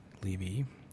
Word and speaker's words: Leevee: This is a band called The Leavies, and that's spelled L-E-E-V-E-E, Leavy.